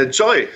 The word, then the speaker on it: Joyy: Äh, Joy, sag einfach Joy dazu. Und, aber du hast recht, die haben vor kurzem den Namen äh, geändert. Vorher hießen sie YY und haben sich in Joy umbenannt.